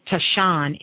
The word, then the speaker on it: tisane: But when you're dealing with this, you're actually dealing with, it's called T-I-S-A-N-E, okay? This is what these herbs are made of. Now, what does that mean? Tashan is how they pronounce it, Tashan.